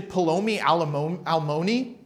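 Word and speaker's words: Peloni Almoni: The Hebrew word there is actually Paloni Almoni, which is kind of funny. It rhymes. It's meant to rhyme. Like, you know, all that, like Paloni Almoni, okay?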